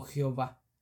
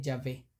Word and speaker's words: Yahvé: Durante algún tiempo, las letras YHWH fueron pronunciadas erróneamente como Jehová, en lugar de Yahvé. Yahvé.